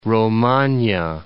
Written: Romagna

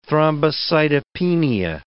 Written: thrombocytopenia

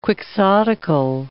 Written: quixotical

